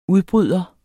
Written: udbryder

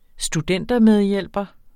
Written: studentermedhjælper

